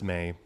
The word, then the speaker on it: Mai: Uh, it's May. – May. But I go by either May or My.